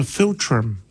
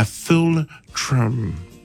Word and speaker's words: philtrum: It's called a philtrum. A philtrum. But it's trum. Philtrum. It's spelled F-H-I-L, Phil, and trum, T-R-U-M.